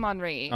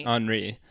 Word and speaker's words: Henri: It's I believe it's spelled Henry. I had the subtitles on. – Oh, okay. – But they call him Henri. – Henri.